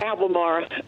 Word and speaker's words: Albemarle: Albemarle.